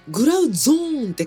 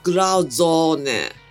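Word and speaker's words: Grauzone: ちなみにグラウゾーネ、 HMV とかタワレコの紹介のところにはグラウゾーンってカタカナで書かれてますけど、ドイツ語の発音ではグラウゾーネグラオですからね、青の発音でゾーンじゃなくてゾーネで、よろしくお願いいたします。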